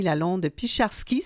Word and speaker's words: Lalonde-Picharski: Oui, avec Hélène Denis jusqu'à 11h30 et j'ai le plaisir d'aller joindre Maître Joanie Lalonde-Picharski. C'est bien comme ça qu'il faut prononcer votre deuxième nom de famille, oui. – Madame Lalonde-Picharski, tant mieux. – Tout à fait, exactement.